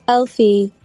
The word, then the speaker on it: Elphi: So it's Elphi.